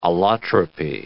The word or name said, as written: allotropy